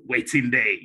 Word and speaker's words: WetinDey: It's a West African slang, which means what's happening, you know, waiting day, you know. So if I was to spell it, it's by saying waiting and day, well, it's pronounced Waiting Day.